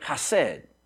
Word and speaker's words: chesed: But anyway, the Hebrew word for loving kindness, we would pronounce it chesed.